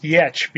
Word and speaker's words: Giertz: Her last name is spelled G-I-E-R-T-Z. It's pronounced Yetch because it's she's Swedish, I think.